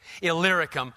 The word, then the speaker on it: Illyricum: So that from Jerusalem and all the way around to, if you want to know how to pronounce that word, just look for the little word Lyric inside Illyricum. Makes it a lot easier. I failed a lot of times trying to pronounce that word until you see that little word Lyric in there, then you're set.